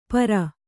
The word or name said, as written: ಪರು